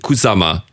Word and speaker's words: Kusama: Ach, Yayoi Kusama.